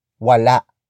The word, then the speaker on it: wala: Wala. Wala. Wala. Wala is spelled as W-A-L-A.